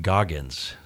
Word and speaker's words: Goggins: So I saw an interesting uh, quote that Mr. Holt put up uh, about a role model that you have, David Goggins.